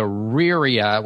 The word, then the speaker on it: Riyria: And several people recommended the Riria, which is spelled R-I-Y-R-I-A, the Riria Revelations by Michael J.